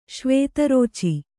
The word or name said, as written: ಶ್ವೇತರೋಚಿ